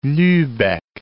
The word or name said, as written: Lubeck